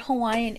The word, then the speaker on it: Hawaiian: But the word Hawaiian is an English word. So it's pronounced with the W.